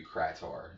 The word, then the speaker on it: Krator: K-R-A-Y-T-O-R? – Oh, I just, without the Y, K-R-A-T-O-R. That could be Krator, so I think Krator is better.